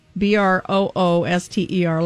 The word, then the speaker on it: Brooster: Kickin' Chicken, Brewster, Brooster, like rhymes with rooster.